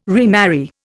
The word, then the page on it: remarry: Indian